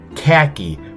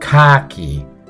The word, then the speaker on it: khaki: In the States, we say the word khaki for those sand-colored cotton trousers. But in Britain, khaki is pronounced more like khaki, since khak is British slang for excrement.